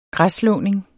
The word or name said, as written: græsslåning